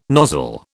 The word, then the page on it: nozzle: British English